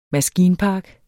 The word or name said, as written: maskinpark